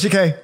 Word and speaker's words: Takei: All right, George Takei rhymes with okay.